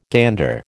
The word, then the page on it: gander: American English